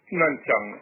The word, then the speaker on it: Nanchang: Como ya quedó dicho, usted se encuentra en la ciudad de Nanchang. ¿Se pronuncia así? – Nanchang, efectivamente.